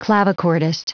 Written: clavichordist

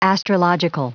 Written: astrological

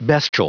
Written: bestial

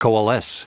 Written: coalesce

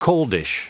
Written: coldish

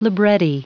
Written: libretti

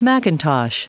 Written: macintosh

